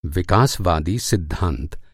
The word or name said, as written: विकासवादी